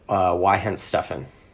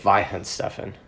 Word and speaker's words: Weihenstephan: which comes from the German brewery Weihenstephan. – Interesting. – Or is it Weihenstephan? Don't they pronounce W's like V's? – Yeah, probably.